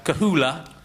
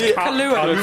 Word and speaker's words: Kahlua: Vodka, Kahula, tonic water. Is that how you say it? – No, it's Kahlua.